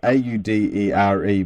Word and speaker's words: Audere: It's pronounced Audere, but uh, it's probably one of the most mispronounced things of all. It's a, it's a, it's, it's a Latin word which I happen to sort of uh, decide was a good name for the business.